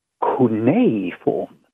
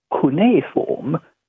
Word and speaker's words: cuneiform: And the answer seems to be that Gilbert and Sullivan rhymed the word cuneiform to go with uniform, mispronouncing it as cuneiform.